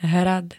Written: hrad

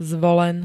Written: zvolen

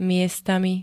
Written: miestami

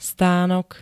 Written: stanok

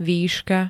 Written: výška

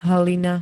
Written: hlina

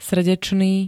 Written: srdečný

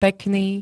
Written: pekný

